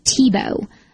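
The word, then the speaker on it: Thibault: One of our more astute listeners told me that actually pronouncing Thibaut is like Tebow, which my first thought was, that sounds exactly like Tim Tebow, but it's spelled totally different.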